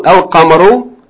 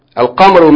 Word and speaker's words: al-qamaru: For example, al-qamaru, the lamb is pronounced. Al-qamaru means the moon. It is pronounced al-qamaru. The lamb is not merged. It doesn't go away.